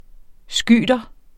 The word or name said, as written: skyter